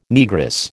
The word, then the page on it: Negress: American English